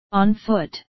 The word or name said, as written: on foot